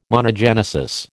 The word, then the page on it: monogenesis: American English